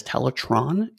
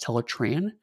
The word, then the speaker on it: Teletran: And he constantly refers to the computer that the Transformers use as Teletron, even though it's consistently pronounced Teletran,